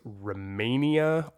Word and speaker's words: Rehmannia: It's Rehmania, R-E-H-M-A-N-N-I-A, which is a traditional Chinese medicine herb that has been shown to also cleanse the kidneys.